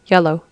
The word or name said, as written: yellow